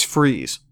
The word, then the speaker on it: Freeze: 400 men, under the command of a man named John Freeze, it's spelled like fries, but it's Freeze, will march to Bethlehem, surround the Sun Inn, and demand the release of those 12 prisoners.